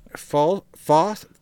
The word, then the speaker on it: Faust: So, Faust is how you say that name.